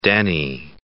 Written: deni

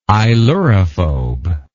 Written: ailurophobic

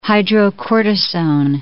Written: hydrocortisone

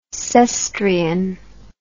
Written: Cestrian

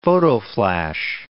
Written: photoflash

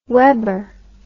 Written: webber

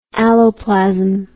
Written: alloplasmic

